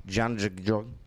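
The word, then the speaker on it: Jahangir: Now, Metro Coronavirus Task Force Chair Dr. Alex Janjigjong, okay, that's how it's pronounced.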